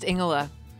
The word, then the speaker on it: Ingela: It's pronounced Ingela. – Ingela. This is one of the things that you do where your name is Ingela, but when I say Ingela, you say I'm pronouncing it wrong. – Correct, because you are. – Because you don't actually pronounce the G. – Correct.